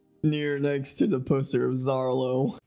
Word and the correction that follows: Zorro: Oh, Zorro.